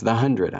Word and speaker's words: The 100: We're supposed to say the 100. – I guess you would know. – No, everybody, they said, no, our official pronunciation is the 100. I have to get used to it. It just sounds like it's missing a word.